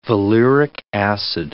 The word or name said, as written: valeric acid